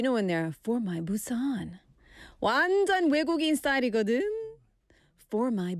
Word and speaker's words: Busan: In Scotland, we don't say Busan. Busan, short A. In Busan, we say Busan.